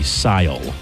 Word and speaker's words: Cyle: Sile, can you spell that, please? – C Y L E, Sile.